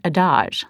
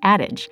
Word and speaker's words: adage: I'd always pronounced it adage, like the old adage, but then my director, Gordon, informed me that actually you pronounce it like adage.